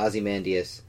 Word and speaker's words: Ozymandias: Like, the only, other than Manhattan, the only one who was actually supposed to be even slightly super was Ozymandias, or however you pronounce it. Ozymandias? – Yeah, Ozymandias.